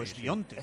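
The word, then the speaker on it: Świątek: Bien lo has dicho, Swiatek. – Swiatek, que estuvimos ayer comprobando cómo se pronuncia. – Bueno, para nosotros siempre es Swiatek. – Pero no, si dices Swiatek, lo dijo ella misma.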